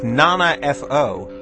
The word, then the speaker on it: 7FO: It's Nana F.O. The group name or the project name is spelled 7 F.O., but that is apparently pronounced Nana F.O.